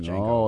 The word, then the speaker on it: Django: And it's the D is silent. It's just Django.